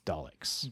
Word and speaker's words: Daleks: The first card from the deck brings the Dalkins to magic. – I do know. It's pronounced Daleks.